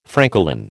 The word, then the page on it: francolin: American English